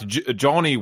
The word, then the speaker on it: Johnny: Like, you wouldn't say to a child, your name is Johnny. When you write your name, you must include this silent H in the middle of it.